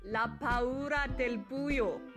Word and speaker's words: La paura del buio: La paura del buio, udtales det.